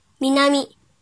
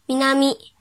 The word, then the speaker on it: 南: 训读的时候读作“ みなみ”，“ みなみ”。